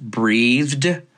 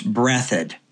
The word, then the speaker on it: Breathitt: Okay, just as an aside, it reads like it should be pronounced breathed, but no, it's Breathitt.